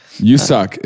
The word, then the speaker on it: Woo-suk: Woo-suk is how you pronounce it. – Woo-suk. Yeah. It's Woo-Suk. – Okay. – But I'm like 99% sure it's not Woo. It's you. That's what, okay. How I heard everybody on the internet pronounce it.